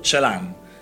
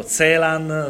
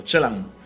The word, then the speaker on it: Celan: Eh, allora, va detta una cosa, perché il cognome viene pronunciato in 3000 modi e mai l'ho sentito pronunciare correttamente. Paul Celan perché viene pronunciato Zelan, Selan, Zelan perché lui è rumeno dell'etnia tedesca di Romania,